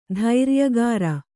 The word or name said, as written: ಧೈರ್ಯಗಾರ